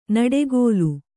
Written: ನಡುಗಾಲ

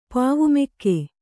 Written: ಪಾವುಮೆಕ್ಕೆ

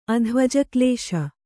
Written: ಅಧ್ವಜಕ್ಲೇಶ

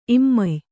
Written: ಇಮ್ಮೆಯ್

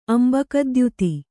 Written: ಅಂಬಕದ್ಯುತಿ

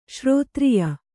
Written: ಶ್ರೋತ್ರಿಯ

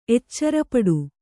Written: ಎಚ್ಚರಪಡು

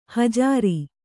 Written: ಹುಜರು

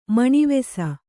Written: ಮಣಿವೆಸ

